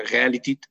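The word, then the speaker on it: Realität: Pues, viene, en alemán existe también la otra palabra escrita con diéresis, realitat, que creo que se pronuncia realitit, no es la que usa Hegel, usa esta otra para referirse al mundo humano.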